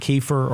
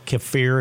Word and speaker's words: kefir: Now I am using, I drink kefir or kefir, if you could pronounce it either way, that will help to implant some.